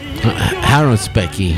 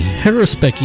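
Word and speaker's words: haruspicy: how do you say it, haruscopy? – Haruspicky. – Haruspicky. He does his haruspicky.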